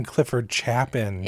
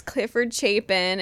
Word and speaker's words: Clifford Chapin: We've been saying Clifford Chapin. – It's Clifford Chapin. And I was so sad when they said his name on the stage. And I was like, that's not how I've been saying it. – I got mixed up with the name Chaplin or something. I was just mixed up when I first pronounced that, I think.